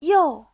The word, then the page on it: you: you4